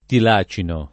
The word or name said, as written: tilacino